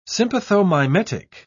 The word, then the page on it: sympathomimetic: British English